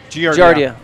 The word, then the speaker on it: Giardia: I'll tell you, because I've had, is it pronounced Giardia or Giardi? – Giardia.